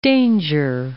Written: Danger Islands

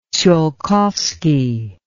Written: tsiolkovsky

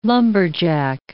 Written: lumberjack

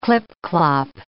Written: clip-clop

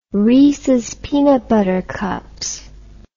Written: reeses peanut butter cups

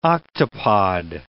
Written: octopod

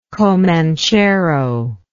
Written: comanchero

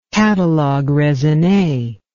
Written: catalogue raisonne

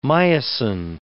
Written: myosin

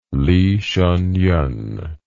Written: Li Hsiennien

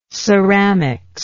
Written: ceramics

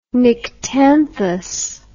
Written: nyctanthous

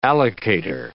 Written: allocator

